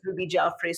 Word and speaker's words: Ruby Jaffrey: So the website is Ruby Jaffrey. So my name is spelled R-U-B-Y-J-A-F-F-R-E-Y, and that's exactly the website.